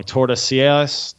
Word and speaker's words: Tordesillas: Yeah, but it was the Treaty of, I always say this wrong, Tordesillas. I don't know. – Yeah, that's how you say it.